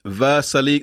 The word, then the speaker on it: Vrsaljko: That's how you say it. James, throw it up. It's Vrsaljko. That's right, Sean. It's Vrsaljko.